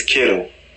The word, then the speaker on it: Kittle: And then you have George Kittles, who is at number 7 at the 49ers. Huh? – George Kittles. – Is it not that? – It's Kittle. – Oh, I added an S, didn't I? – Yeah.